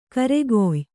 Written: ಖೈರ್‍ಕಾಯಿ

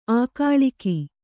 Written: ಆಗಳಿಕೆ

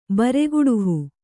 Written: ಬರೆಗುಡುಹು

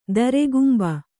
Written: ದೃಗಂಬು

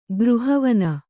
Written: ಗೃಹವನ

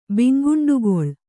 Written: ಬಿಂಗುಂಡುಗೊಳ್

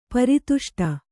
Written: ಪರಿತುಷ್ಟೆ

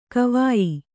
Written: ಗವಯ

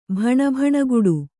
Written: ಭಣಭಣಗುಡು

